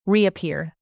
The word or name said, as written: reappear